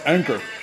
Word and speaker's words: Anchor: I apologize, I believe it's pronounced Anchor. Anchor, yes, that's it.